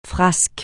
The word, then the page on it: frasques: francais